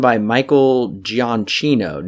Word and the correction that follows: Giacchino: Now, how ah. – familiar are you with him, Alan? – Pronounced, I think, uh, Giacchino.